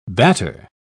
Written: batter

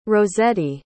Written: rossetti

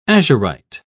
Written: azurite

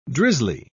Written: drizzly

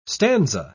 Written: stanza

